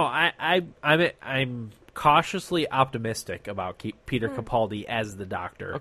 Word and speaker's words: Peter Capaldi: But from what I understand, this gentleman, Peter Capaldi, did I pronounce that right? – Yeah.